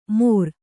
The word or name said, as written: ಮೊರ